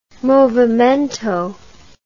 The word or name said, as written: movimento